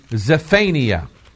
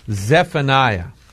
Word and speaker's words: Zephaniah: Now let's go to Zephaniah. Did I say that right? No. Zephaniah is where we're going to go.